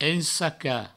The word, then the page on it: ensacar: ensaca; provençal